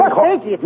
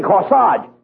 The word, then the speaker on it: corsage: Corsage? – No. You mean corsage. A-G-E is pronounced odd, as in corsage and garage.